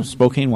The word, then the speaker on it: Spokane: Well, that was our friend Josh from Spokane. Yeah, I think you said it right, Lisa. Oh. Spokane, Washington.